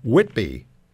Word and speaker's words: Whitby: Bill in Whitby, Ontario. The H is not pronounced.